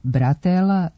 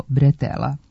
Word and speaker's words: bretela: Međutim, naremenica kao deo grudnjaka, potkošulje, kombinezona, letnje majice ili haljine, remen ili kaiš za nošenje tereta nije bratela, nego bretela. Potiče od francuske reči bretel, koja u izgovoru ima nemo e, a ono se u naš jezik prenosi kao e.